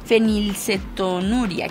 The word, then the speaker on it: fenilcetonuria: También es el Día Mundial de la Fenilicetonuria. Sí lo dije bien, sí, fenilcetonuria.